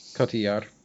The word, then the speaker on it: Cotillard: Cotillard.